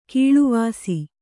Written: ಕೀಳುವಾಸಿ

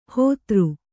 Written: ಹೋತೃ

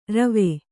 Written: ರುವಿ

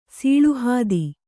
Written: ಸೀಳುಹಾದಿ

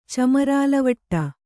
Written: ಚಮರಾಲವಟ್ಟ

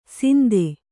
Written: ಸಿಂದೆ

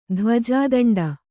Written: ಧ್ವಜಾದಂಡ

